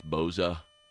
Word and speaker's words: Bosa: Bosa. – It's Boza. Like a Z, it's Boza.